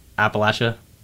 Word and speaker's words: Appalachia: Appalachia. That's how it's pronounced.